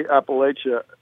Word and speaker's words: Appalachia: I know you pronounce it Appalachia. How do you say Appalachia? – Yeah, I say Appalachia. You know, we're right at the point. I think the Mason-Dixon line, I think below the Mason-Dixon line, it's Appalachia, largely. People pronounce it that way. And I think above the Mason-Dixon line, it's Appalachia. We're very close to the Mason-Dixon line. It's an hour south of us. So we get both pronunciations here.